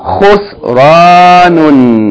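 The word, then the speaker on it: Khusranun (خسران): Khusranun. Three syllables. Khusranun. Remember the rule for sukoon is that whenever a letter has sukoon, you join it to the letter before it, making one syllable.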